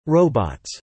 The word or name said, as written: robots